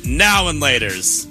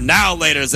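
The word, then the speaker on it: Now and Later: I say now and laters. You say now laters, like it's all one word.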